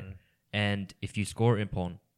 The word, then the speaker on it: ippon: Ippon.